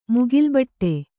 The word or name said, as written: ಮುಗಿಲ್ಬಟ್ಟೆ